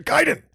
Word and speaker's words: Gaiden: Because everybody's like, is it Gaiden or Gaiden? This one guy's all, Ninja Gaiden!